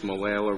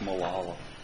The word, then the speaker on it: Molalla: Next is Nuffer versus Molalla River School District. – Close, but not quite. – Close enough. – Not close enough. – What'd you say? – It's pronounced Molalla.